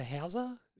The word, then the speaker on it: Hauser: Is it Paul Walter Hauser? – Yes.